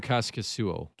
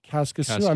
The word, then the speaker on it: Kaskisuo: Casimir Kaskisuo. – Kaskisuo.